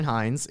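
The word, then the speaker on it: Heiens: At Christian Hines.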